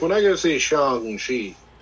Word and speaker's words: Shang-Chi: I also read in their little thing, they said it's pronounced like song, not sang. – Yeah. – Is it? Yeah. Are we going to call it Shang-Chi? – Well, we are.